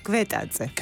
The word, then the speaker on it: Gvetadze: Kwetaatse.